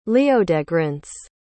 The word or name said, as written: leodegrance